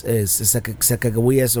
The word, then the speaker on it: Sacagawea: But the ranger said that the Mandan language, or actually the Shoshone language, which is Sacagawea's native language, didn't have a strong K sound, so it was most likely pronounced Sacagawea, even though I believe Lewis and Clark spelled it with a J.